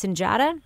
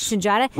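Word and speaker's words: Sunjata: I think his last name is pronounced Sinjata. – Yes. – Sinjata.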